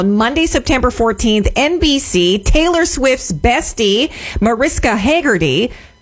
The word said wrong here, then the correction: Hargitay: Is that how you say it? – Hargitay. – Hargitay? – Yep.